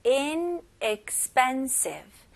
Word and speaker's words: inexpensive: 그래서 inexpensive. Inexpensive. 그리고 여기 첫 번째 e에는 에라고 발음 안 하셔도 돼요. 그냥 에. Inexpensive. Inexpensive. 이렇게 하시면 훨씬 더 빨리 말을 할 수 있습니다.